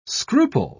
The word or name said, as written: scruple